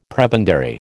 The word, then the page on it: prebendary: American English